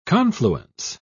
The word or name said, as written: confluence